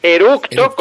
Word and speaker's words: eructo: Y eruto así, entre, o sea, E-R-U-T-O, eruto tampoco se puede decir porque está mal dicho. Eructo con C de casa.